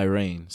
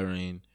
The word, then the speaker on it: Rein: is Love by Rain. So it's at Love by Rain. For my music is Rain Focused, and all of this is spelled R-E-I-N, by the way, which is my real name, like on my birth certificate and everything.